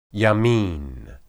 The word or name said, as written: yamin